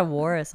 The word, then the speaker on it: walrus: Did I say it wrong? – Walrus? – Is it? Yeah, it's like how I say drawer. I can't say drawer right. Drawer. – Yeah, it's walrus. – Yeah, there's an L in there.